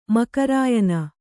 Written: ಮಕರಾಯನ

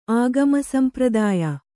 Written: ಆಗಮಸಂಪ್ರದಾಯ

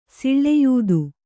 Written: ಸೂಳಾಯಿತ್ತ

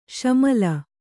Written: ಷಾಮೀಲು

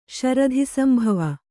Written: ಶರಧಿಸಂಭವ

